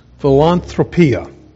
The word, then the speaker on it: philanthropia: And it's because there's a rather unique word here pronounced philanthropia. Does that sound like philanthropy? That's where that word comes from, philanthropia.